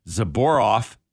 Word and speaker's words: Zelboraf: The Roche, they're saying Roche's new AG drug, Zeboraf, that's spelled Z-E-L-B-O-R-A-F, for treating molecular roots of tumors and therapies like Yervoff.